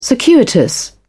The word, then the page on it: circuitous: British English